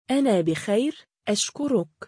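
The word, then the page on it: أنا بخير أشكرك: Arabic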